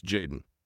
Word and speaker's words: JXDN: Today, we are talking with singer-songwriter Jaden Isaiah Hosler, otherwise known as JXDN, pronounced Jaden.